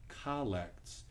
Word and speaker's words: collect: As we enter into the time of prayer, you might think that's a misprint there, collects. It's actually, in this instance, pronounced collects. And